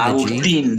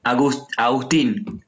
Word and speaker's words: Augustine: Augustine. The emphasis is on the last E. Augustine.